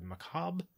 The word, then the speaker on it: macabre: The next installment of True Detective tells the story of a macabre. I don't know how to ever pronounce that word. Macabre? – Macabre. – M-A-C-A-B-R-E. It's like macabre. Macabre.